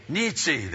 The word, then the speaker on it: Nietzsche: We add to that this guy named Nitz or whatever his name is. It's N-I-E-T-Z-S-C-T-H-E. How do you pronounce that? Nietzsche, then. Great.